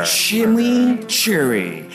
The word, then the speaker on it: chimichurri: Chimichurri.